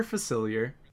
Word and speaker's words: Facilier: Facilier? Yeah, Dr. Facilier.